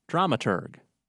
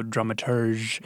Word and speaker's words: dramaturg: I started by asking them to explain the term dramaturg. – Dramaturg, it's a German term, so we'll say the hard G. If it was French, we'll go dramaturge.